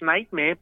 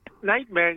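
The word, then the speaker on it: NGHTMR: You also heard No Air, the remix by Nightmare, all caps, no I. We think it's pronounced Nightmare, but, I mean, we can't be sure. Mm-mm. Nightmare, no I and no A, according to the script,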